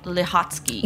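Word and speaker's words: Schütte-Lihotzky: Margaret Chytil-Hotsky. – Yeah, I believe that is the correct pronunciation.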